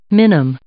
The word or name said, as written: minim